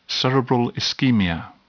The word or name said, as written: cerebral ischemia